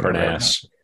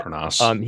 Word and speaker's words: Montparnasse: He buys himself a cemetery plot in Montparnasse. I'm not sure if I'm pronouncing Montparnasse. – That right. – Yeah. Montparnasse.